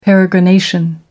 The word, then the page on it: peregrination: American English